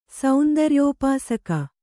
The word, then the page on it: ಸೌಂದರ್ಯೋಪಾಸಕ: saundaryōpāsaka